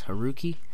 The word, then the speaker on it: Haruki: But it's pronounced Haruki. And the U in Japanese is pronounced very interestingly. It's not u, like tu in Spanish. It's u, like tsunami.